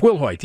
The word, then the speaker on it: Wilhoit: Let me get a great pronunciation. It's Wilhoit. Yeah, Kathleen Wilhoit.